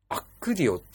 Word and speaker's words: ACCUDIO: イヤホンの性能を最大限に発揮できるアプリというので、で、アクディオっていうんです。アクディオっていうのかな。 ACCUDIO って読むんですけどね、これ。